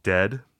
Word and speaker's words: Dehd: Dead. Dead is a band, and you know, it sounds like The Grateful Dead, but it's just Dead, D-E-H-D is how it's spelled.